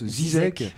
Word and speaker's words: ZZK: En fait, elle est enregistrée sur un label de disques qui s'appelle ZZK en français, mais ça se prononce Zizek en Argentine.